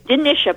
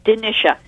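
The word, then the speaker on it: Dinitia: It's Denisha, by the way, is the pronunciation of my name. It's Denisha.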